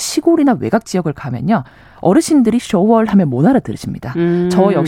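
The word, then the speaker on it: 쇼월 (首尔): 근데 지금은 이것을 저희가 쇼월이라고 부릅니다. 네. 굉장히 흡사한 발음인데 예전에는 이걸 중국식으로 한청, 한성이라고 불렀습니다. 아. 그래서 저희가 이거를 쇼월로 바꾸는 노력을 굉장히 했고요.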